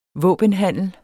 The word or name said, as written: våbenhandel